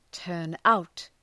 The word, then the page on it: turn out: ‘tɜ:rnaʋt